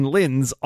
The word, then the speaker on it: Linz: Glenn Fleischman, who of course is cut from the same cloth, reports that Link ate a bunch of sausage links in Linz, Austria.